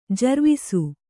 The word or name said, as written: ಜರ್ವಿಸು